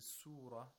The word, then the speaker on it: surah: You have to make sure that it's pronounced like a sad and not a seen. If you say fi ayy surah, surah, it's not a sad.